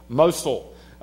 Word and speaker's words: Mosul: Mosul.